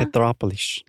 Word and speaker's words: Petrópolis: Mas é só pensar em petróleo. E aí Petrópolis. – Tá, então minha pronúncia está correta agora, tá. Petrópolis. – Tá ótimo.